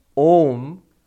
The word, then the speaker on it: Om: So, when you pronounce Om, you don't pronounce Aum. You don't pronounce it as Aum. I've heard people doing that. That comes after a little bit of knowledge of Vedanta. What they were doing earlier is correct. Om is correct.